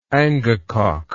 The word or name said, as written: angakok